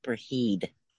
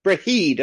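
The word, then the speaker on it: Brigid: Um, but Breit um, is one of those that, that I chose. – Huh? Her name is pronounced Braheed. Braheed. Okay.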